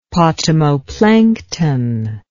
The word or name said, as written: potamoplankton